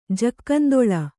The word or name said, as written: ಜಕ್ಕಂದೊಳ